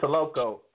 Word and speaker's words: TULOKO: It's pronounced TULOKO? – Correct. It's TULOKO. And it's, as you know, heard earlier, a play on Tulsa, Oklahoma.